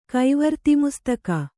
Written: ಕೈವರ್ತಿಮುಸ್ತಕ

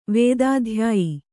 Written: ವೇದಾಧ್ಯಾಯಿ

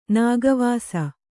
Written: ನಾಗವಾಸಿ

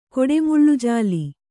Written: ಕೊಡೆಮುಳ್ಳುಜಾಲಿ

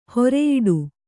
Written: ಹೊರಿಯೊಟ್ಟೆ

